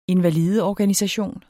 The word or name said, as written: invalideorganisation